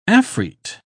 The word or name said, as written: afreet